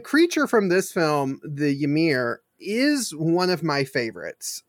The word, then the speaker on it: Ymir: This creature, the Ymir or Ymir or however you want to pronounce it. – Ymir, I've heard it's been, I've seen it pronounced and heard it pronounced several different ways.